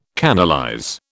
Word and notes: canalize: British English

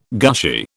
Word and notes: gushy: British English

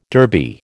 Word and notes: Derby: American English

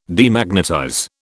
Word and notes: Demagnetize: British English

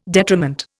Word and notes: detriment: Indian

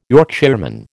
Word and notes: Yorkshireman: American English

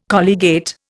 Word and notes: colligate: Indian

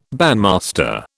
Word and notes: bandmaster: British English

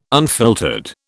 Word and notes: unfiltered: British English

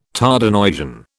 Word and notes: Tardenoisian: British English

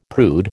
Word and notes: prude: American English

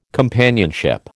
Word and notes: companionship: American English

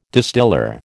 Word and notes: distiller: American English

